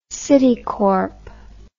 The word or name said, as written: citicorp